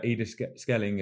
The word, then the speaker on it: Ide Schelling: Tom actually trains with uh, Ide Schelling.